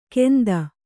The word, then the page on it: ಕೆಂದ: kannada